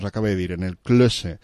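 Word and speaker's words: Klöße: i una cosa que se diu Klosse, que s'escriu en una Eszet, que és una espècie de beta, però que se pronuncia S, i que ho he vist en aquestes superfícies més d'una vegada i de dos, ahir a la venda.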